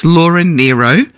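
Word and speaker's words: Laura Nyro: I did look up Laura, how to pronounce Laura's name, and it's Laura Nero. Because she was born Laura Nigro and then changed it to Laura Nero, but spelt with a Y.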